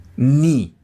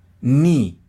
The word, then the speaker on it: knee: Knee. Knee. ¿Qué significa? Rodilla. Rodilla. ¿Cómo que se te olvidó? Knee. Knee. ¿Y si fueran las dos? Knees. Knees.